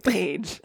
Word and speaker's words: Page: I didn't mean to pronounce his last name weirdly, Page.